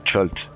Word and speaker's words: Chult: Toi, tu as, tu as exploité euh, Schult, D'ailleurs, tu le prononces comment Alors, moi, je dis le Chult pour éviter le chute.